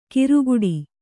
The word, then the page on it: ಕಿರುಗುಡಿ: kiruguḍi